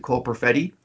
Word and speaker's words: Perfetti: Is that how you pronounce it? Cole Perfetti? – Yep.